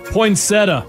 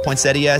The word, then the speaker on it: poinsettia: Poinsettia. – Except. Correct. Poinsettia is how it's spelled, but I say poinsettia.